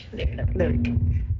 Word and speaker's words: Lyriq: Lyriq.